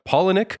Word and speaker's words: Palahniuk: It's based on the book by Chuck Palahniuk,